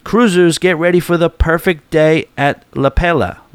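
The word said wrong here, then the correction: Lelepa: Wait, hold on. I think I know how to say this. La Lepa.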